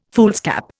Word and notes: foolscap: Indian

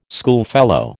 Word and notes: schoolfellow: American English

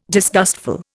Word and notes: disgustful: Indian

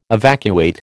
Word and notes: evacuate: American English

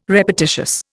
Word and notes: repetitious: Indian

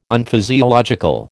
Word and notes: Unphysiological: American English

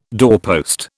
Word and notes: doorpost: British English

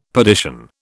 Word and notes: perdition: British English

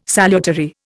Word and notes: salutary: Indian

